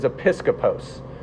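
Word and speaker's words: episkopos (ἐπίσκοπος): The original word, because everybody cares about this, is episkopos. Okay? And that's spelled, for you note takers, E-P-I-S. So E-P-I-S-K-I-P-O-S.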